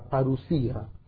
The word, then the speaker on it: parusía: La parusía. Parusía. Se escribe en griego así. Parousia. La parusía con una O ahí metida.